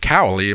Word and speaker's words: Cowley: And we're talking your producer, Patrick Cowley. Am I pronouncing his last name right? – Yes.